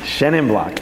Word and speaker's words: Chenin Blanc: And some of those people have now grafted Shenna for me. Or just, sorry, I can't believe I pronounced it with a French accent. That I want stricken from the record. Jesus Christ. Chenin Blanc. Thank you.